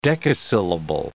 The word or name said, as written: decasyllable